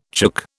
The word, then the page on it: chook: British English